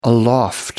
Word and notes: aloft: American English

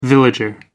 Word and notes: villager: American English

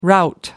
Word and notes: rout: American English

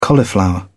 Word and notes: cauliflower: British English

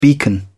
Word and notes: beacon: British English

